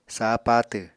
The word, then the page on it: sah patw: Apatani